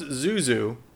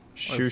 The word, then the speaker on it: Xiu Xiu: X-I-U-X-I-U. – Shushu? – Maybe, yeah. Shushu? Is that how you would pronounce it? – I think if it's referencing Chinese, an XI mm-hmm. sound is pronounced sh. – So shushu? – Shushu.